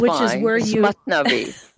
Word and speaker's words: Mathnavi: So I wanted to start out, I guess, first of all, I had asked you about the Mathnawai. I know I'm going to butcher that again. – It's Mathnavi. Yes.